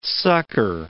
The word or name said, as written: sucker